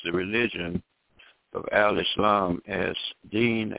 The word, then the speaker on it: Deen: In the Holy Quran, Allah describes the religion of Al-Islam as Deen, pronounced as though it was spelled D-E-E-N. Deen al-Fitra, Fitra, pronounced as though it was spelled F-I-T-R-A-H, which means the religion established on the laws and principles which uphold the creation.